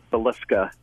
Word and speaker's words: Villisca: So, am I saying it right? Villisca? – I've heard it a million ways. It's actually pronounced Villisca.